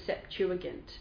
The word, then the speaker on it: Septuagint: The Greek is the Septuagint, if I'm pronouncing that right.